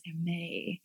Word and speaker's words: Hermes: Oh no, while I was doing it, they're like, "I'm sorry, actually the correct pronunciation is Hermes." – Oh, you don't say the S.